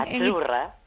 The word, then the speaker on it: Azzurra: Sí, como porque pizza, la Z no es la Z como decimos en catalán tan sonora, no. Ah, es Azzurra.